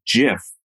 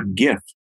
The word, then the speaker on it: GIF: Now, the inventor, a chap by the name of Stephen Wilhite, insists that the proper pronunciation is GIF, like jelly, not GIF, like giddy.